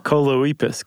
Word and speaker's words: Choloepus: There are the two toed uh, I was going Colopus? with col- Oh. Choloepus. Choloepus. Choloepus. It rhymes with Bocephus.